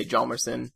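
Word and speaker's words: Hjalmarsson: There's like a few pronunciations. Some put more emphasis on the H. Some ignore it completely and just say Jalmerson.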